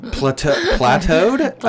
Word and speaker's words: plateaued: Yeah, I want to say plateaued. – No, you don't. – Plateaued? I don't want to say it, actually. Okay, so what I said's not a thing. How do you say that word? – Plateaued.